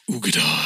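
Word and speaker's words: Uigeadail: Ugedal. – Also, das klingt aber ein bisschen, wenn ich das sagen darf, wahrscheinlich falsch ausgesprochen. Nichts gegen dich. Aber oder spricht man so aus? – Man spricht so aus.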